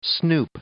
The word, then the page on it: snoop: verb